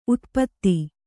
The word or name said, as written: ಉತುಪಾತ